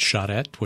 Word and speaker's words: charrette: By the way, I compliment you on pronouncing it charrette, which is really a great thing.